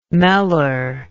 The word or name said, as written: meller